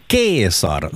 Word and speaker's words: Caesar: Julius Caesar eller Julius Caesar som det ska uttalas egentligen. Jag har studerat latin, så det här är det enda jag minns från mina latinstudier. – Så vi borde äta Caesarsallad. – Vi borde äta Caesarsallad. C ska uttalas K.